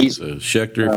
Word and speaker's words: Schecter: I like saying Schecter. Good. It just rolls off the pretty good. – It rhymes with sphincter. That's why you like it. – Schecter, yeah, sphincter. Yeah, it does.